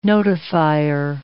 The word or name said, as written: notifier